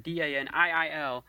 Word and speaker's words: Daniil: I guess it's Daniel or similar to Dan. Daniil. And then KVY. – Daniil Kiyawai. – Yes, that's it right there.